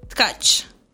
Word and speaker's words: Tcaci: Katya, and your last name is Ush? – No. – No. – Tkach. – There it is. – Can you spell that? – T-C-A-C-I.